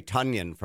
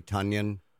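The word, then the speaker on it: Tonyan: I heard people pronounce it this way for 7 years, didn't know it was wrong. But I'm going to say Tunyon from now on. Can we all just respect the green and gold and say Tunyon, Commish? – Big Bob Tunyon.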